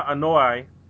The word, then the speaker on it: Anoa'i: the brothers Afa and Sika Anoa'i. Is that how you pronounce their last name? – Yeah, it works for me.